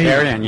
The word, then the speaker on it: carrion: Uh, the largest one is that is a carrigan. Uh, am I saying it right? The the flower smells like uh, dead meat, carrion, yeah.